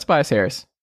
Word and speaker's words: Tobias Harris: I said his name right, Tobias Harris. I'm making sure. – It's Tobias Harris.